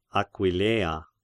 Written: Aquileia

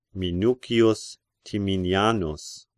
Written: Minucius Timinianus

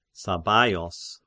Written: Sabbaios

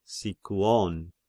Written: Sikyon

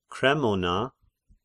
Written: Kremona

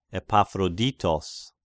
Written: Epaphroditos

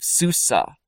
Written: Susa

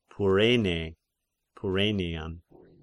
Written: Pyrenean

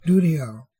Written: Doudael

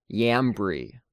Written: Jambri

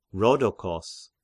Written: Rhodokos

